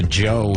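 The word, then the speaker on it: Djo: listening to Joe here. It's D-J-O, but it's pronounced Joe, not J-O-E.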